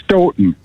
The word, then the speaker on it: Stoughton: You actually, the second time you said it right, Stoughton. They pronounce okay. it Stoughton. – Like Houghton, but Michigan.